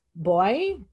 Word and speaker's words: Boix: So as a photographer, I should say his name, Francisco Boix. It's B-O-I-X is his name.